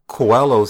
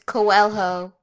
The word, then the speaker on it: Coelho: And so, yeah, when I relate what Coelho says in the book to what Tyson says now. – It's Coelho.